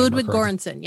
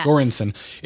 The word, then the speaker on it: Göransson: Ludwig Göransson. Yeah. – Göransson.